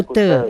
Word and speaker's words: Kuta: Kalau tulisannya gula, kalau bahasa sana kan bacanya gule. – Gule. – Seperti Pantai Kuta, bacanya Kute.